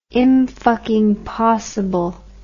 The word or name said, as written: imfuckingpossible